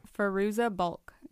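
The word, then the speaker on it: Fairuza Balk: The cast is Feruza Balk. I don't know how to pronounce. Do you know how to... – Feruza Balk, yeah.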